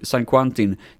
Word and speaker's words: San Quentin: Jag tror det är San Quentino. jag kan inte uttala det. San, San Quentin.